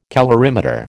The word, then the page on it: calorimeter: American English